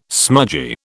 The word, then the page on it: smudgy: British English